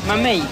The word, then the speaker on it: mamey: Mamey.